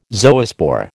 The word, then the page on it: zoospore: American English